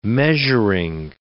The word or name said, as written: measuring